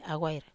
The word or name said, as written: awair